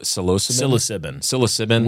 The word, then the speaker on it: psilocybin: Psilocybin.